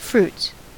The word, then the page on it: fruits: American English